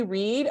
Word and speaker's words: Read: I am Hey Katie Reed across the various platforms right now. My name is KATIEREAD. Everyone wants to spell it with two E's the common way.